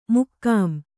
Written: ಮಕಾಂ

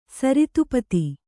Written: ಸರಿತ್ಪತಿ

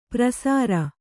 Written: ಪ್ರಸಾರ

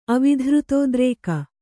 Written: ಅವಿಧೃತೋದ್ರೇಕ

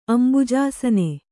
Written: ಅಂಬುಜಾಸನ

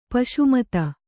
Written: ಪೂಸಿಮಾತು

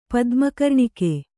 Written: ಪದ್ಮಕರ್ಣಿಕೆ

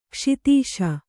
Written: ಕೈಸೋದಿಸು

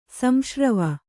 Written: ಸಂಶ್ರವ

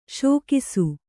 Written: ಸೇಕಿಸು

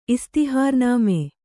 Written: ಇಸ್ತಿಹಾರ್ನಾಮೆ

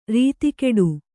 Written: ರತಿಗೆಡು

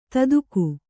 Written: ತದುಕು

